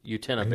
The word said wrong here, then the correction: Uintah: Basin. I think that's how you pronounce it. U-T-I-N-A-H. – U-I-N-T-A-H. – Oh, okay. – Yunta. – Okay. – It's like Utah, but with N inside of it. Yunta.